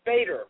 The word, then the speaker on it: Bader: Yeah, it's Bader with a long A.